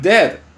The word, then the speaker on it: dead: And dead, it's said like bed, not bead. For goodness sake, don't call it deed.